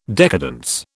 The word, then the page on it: decadence: British English